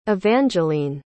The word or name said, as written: evangiline